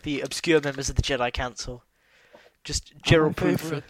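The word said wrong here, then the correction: Yarael Poof: Yarel Poof.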